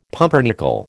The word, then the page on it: pumpernickel: American English